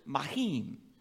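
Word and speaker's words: mahim (מים): The word for water in Hebrew is mahim. Mahim. All right. The word for Jerusalem, and I'm going to butcher this one a little bit, Yerushalem.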